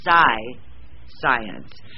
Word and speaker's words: Psience: and it is spelled P-S-I-E-N-C-E, and my first reaction when I looked at this book was Psy, science.